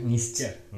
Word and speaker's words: Nietzsche: Kalau nggak salah Nietzsche ya bacanya, Nietzsche gitu. Atau Nietzsche aja ya, Nietzsche.